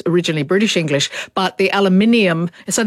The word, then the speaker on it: aluminium: One is, we say aluminium. Americans say aluminum. – Aluminum, yeah.